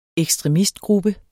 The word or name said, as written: ekstremistgruppe